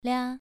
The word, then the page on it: lia: 2